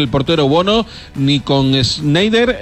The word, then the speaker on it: Schneider: Se te pasa por no confiar en tu pronunciación, que la habías dicho bien, Schneider.